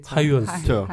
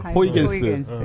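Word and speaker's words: Huygens: 대표적인 게 지난번에 잠깐 얘기했던 하이언스. – 호이겐스. – 분명히 영어를 읽으면 호이겐스인데.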